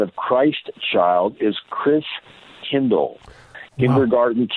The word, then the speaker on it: Christkindl: And in German, the pronunciation of the Christ child is Christkindl. And from that, we get Kris Kringle.